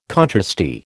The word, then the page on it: contrasty: American English